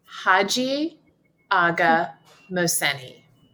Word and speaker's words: Hajiaghamohseni: It's Haji Aga Mohseni.